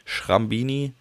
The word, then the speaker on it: Schrambini: Also S-C-H-R-A-M-B-I-N-I. Schrambini.